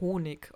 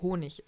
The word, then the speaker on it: Honig: Also ich meine, guck mal zum Beispiel, ähm, sagen auch viele Menschen Honig, obwohl die richtige Aussprache Honig ist. Ja, das mit dem G und Ch.